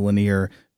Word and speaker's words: Lanier: And Sarah also taunts us for being initially unable to pronounce Jaron Lanier's last name as Lanier, because she points out that we are in Atlanta, which is just a little bit south of Lake Lanier.